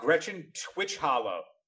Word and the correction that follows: Gretchen Titchwillow: Gretchen Titchwillow. I said it wrong the first time. It's Gretchen Titchwillow.